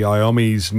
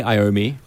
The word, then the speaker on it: Iommi: We kicked off this evening with, Tony Iommi's new single. And I'm always sus about the pronunciation of that. – I say Iommi. – Iommi, yeah. Yummy.